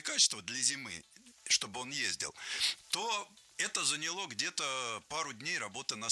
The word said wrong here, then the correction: заняло: Нет, плохо, потому что не заняло, а заняло. Ударение не ставим на окончание ни в коем случае.